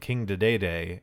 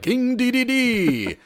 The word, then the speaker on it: King Dedede: I thought for a very long time that you pronounced his name King Dedede, and I was extremely wrong. This is not how you say his name.